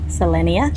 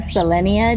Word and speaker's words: Selenia: Selenia.